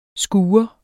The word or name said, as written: skure